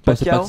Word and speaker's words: Pacquiao: Pacquiao. – Ouais, j'arrive Pacquiao.